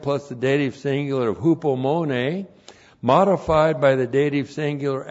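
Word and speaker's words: Hupomone (ὑπομονή): Hupomone. H-U-P-O-M-O-N. Long E. Hupomone.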